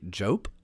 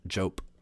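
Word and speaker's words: Diop: This is Mackey Jope, I think is the way it's pronounced. It's D-I-O-P, but I think it's pronounced Jope. If I'm wrong, this is another time that I've embarrassed myself on this podcast.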